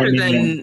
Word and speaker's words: aluminium: You know, every time um, every time I realize that the Brits say aluminium, or I guess in this case Australians too, I'm just bowled over. Aluminium laboratory. – Yes. – Um, it's like putting the accent on a different syllable.